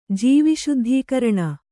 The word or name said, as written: ಜೀವಿಶುದ್ಧೀಕರಣ